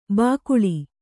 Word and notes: ಬಾಕುಳ: bākuḷa

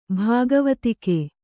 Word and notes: ಭಾಗವತಿಕೆ: Kannada

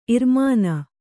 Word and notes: ಇರ್ಮೊನೆ: irmone

